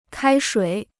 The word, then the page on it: 开水: kāi shuǐ